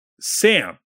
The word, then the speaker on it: S9MM: And then your the other DPS player they brought in is Sam. It's S9MM. It's pronounced Sam.